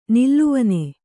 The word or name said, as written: ನೆಲವೆಣ್ಣು